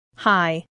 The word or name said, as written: high